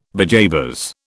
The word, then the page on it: bejabers: British English